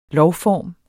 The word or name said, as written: lovform